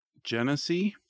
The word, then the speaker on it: Genesee: Also, it's pronounced Genesee.